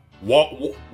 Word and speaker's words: Wallen: And we will be back next week with SNL's return with Jason Bateman and Morgan Wallen. – Wallen. – Is that how we say it? Wallen. – Yep. Wallen. Wallen.